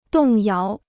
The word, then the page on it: 动摇: dòng yáo